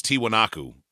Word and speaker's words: Tiwanaku: You know what it is? It's Tiwanaku. – Tiwanaku.